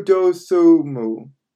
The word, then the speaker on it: Dosumu: But then you have other guys like Ayo Desmayu. I don't know how to pronounce this guy's name. Ayo Dosumu.